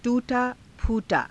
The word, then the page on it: _tuu_taa_phuu_taa: Hindi